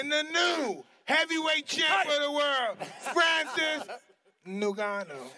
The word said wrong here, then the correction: Ngannou: Have you heard Chris Mannix's constant pron- pronunciation of like Ninganu? Like people are all over the place. Francis Ngannou.